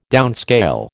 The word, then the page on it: downscale: American English